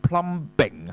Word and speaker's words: plumbing: The plumbing 就係講啲水管啦，啊換曬啲水管，就係 all the plumbing of the house，就唔係 plumbing 啊嚇。咁呢個又考起個 B 字咧，原來唔發音噶。